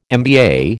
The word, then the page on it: MBA: American English